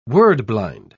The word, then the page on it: word-blind: British English